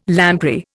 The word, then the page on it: lamprey: Indian